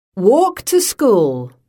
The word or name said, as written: walk to school